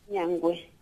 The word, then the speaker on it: Muinjangue: Nyangwe.